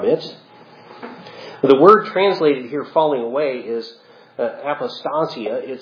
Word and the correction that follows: apostasia: Actually, I put the emphasis on the wrong syllable there. It's apostasia, from which we get the words in English apostasy and apostate.